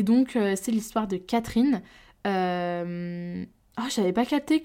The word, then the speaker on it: Catrin: Oh là là, moi je le disais Catherine, parce qu'en fait ça s'écrit C-A-T-R-I-N mais j'avais pas capté que c'était Catherine. Waouh Bref, c'est l'histoire de Catherine, mais écrit C-A-T-R-I-N, donc Catherine.